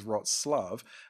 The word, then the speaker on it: Wroclaw: It's spelled Wroclaw. I'm like, Wroclaw. And I'm like, nope, the pronunciation of that is Wroclaw. And I'm like, it looks nothing like that.